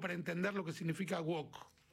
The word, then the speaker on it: woke: Traté de explicarles hoy muy brevemente qué significa el término woke, que se escribe, es un término en inglés que se escribe W-O-K-E, woke, se pronuncia woke.